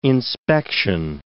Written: inspection

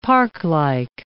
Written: parklike

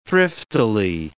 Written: thriftily

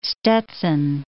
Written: Stetson

